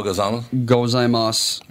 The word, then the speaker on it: gozaimasu: Well, you don't pronounce the U. – You don't, so it's just Ohio gozaimasu? – Gozaimasu.